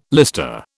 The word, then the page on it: lister: British English